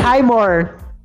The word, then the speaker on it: Highmore: Fred assim, Highmore.